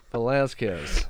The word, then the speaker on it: Velasquez: Velasquez.